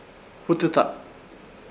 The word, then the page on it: Hutɨta: Ishir; Chamacoco